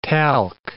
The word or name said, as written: talc